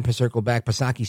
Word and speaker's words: Psaki: There's a change of the guard because Jen Psaki, she's not going to be able to defend Joe El Baboso Biden anymore because Jen Psaki, Silent P, of course,